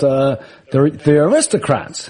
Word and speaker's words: The Aristocrats: There was a screening of this film called The Aristocrats.